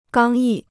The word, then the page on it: 刚毅: gāng yì